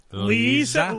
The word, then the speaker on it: Lesia: He'll face Democrat L-E-S-I-A. How would you pronounce that? – Lisa. – Lisa? – Lisa.